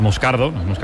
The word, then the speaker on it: Moscardo: Como bien ha pronunciado Unai, es Moscardo, es brasileño, sí, sí.